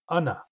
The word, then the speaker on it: ana: In most dialects, the question word for how many is una, with a u in the first syllable, una. Only in East Barkley Sound, and sometimes in West Barkley Sound, the word is pronounced ana.